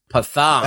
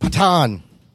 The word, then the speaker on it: Pathan: This is Pathan. Right, uh, it's pronounced Patan. Patan.